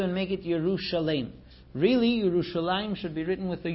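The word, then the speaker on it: Yerushalayim (ירושלים): We pronounce it Yerushalayim, but the writing is like Yer'eh and Shalem, Yerushalem.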